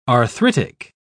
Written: arthritic

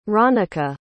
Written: ronica

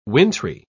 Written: wintry

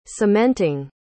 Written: cementing

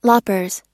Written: loppers

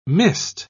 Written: mist

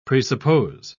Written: presuppose

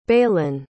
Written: baylen

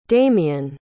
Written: damion

